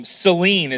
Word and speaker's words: selene: The Greek word that Matthew and Mark use when they wrote this is essentially a form of selene is how we pronounce it in the English, but Selena.